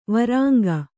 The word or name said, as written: ವರಾಂಗಿ